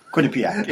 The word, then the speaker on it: Quinnipiac: Quinnipiac, yes.